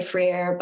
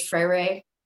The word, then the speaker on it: Freire: In a certain way, this whole conversation really rests on the insight of Herbert Marcuse and Paulo, I used to say Freire, but I think it's Freire. It's hard to pronounce his name,